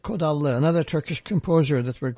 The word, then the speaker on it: Kodallı: Nevit is spelled N-E-V-I-T. Kodallı is K-O-D-A-L-L-I.